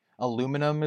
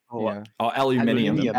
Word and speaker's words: aluminium: Yeah, I mean, there's definitely a lot of those, like, especially between, like, the American pronunciation and then, like, the British pronunciation of a lot of stuff. Like, aluminum is one of them. Like, aluminium.